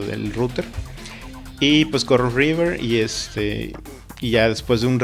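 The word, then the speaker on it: Reaver: Otro que, es otra herramienta que este que sí probé, que se llama, eh, se escribe R-E-A-V-E-R, e e o River.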